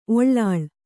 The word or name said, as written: ಒಳ್ಳಾಳ್